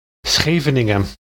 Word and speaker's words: Scheveningen: The Dutch would use the name of a seaside town located near The Hague called Schengenwegen. You know what? I'll just do this. – Scheveningen. – Germans, and pretty much everyone else on the earth for that matter, cannot pronounce the word properly.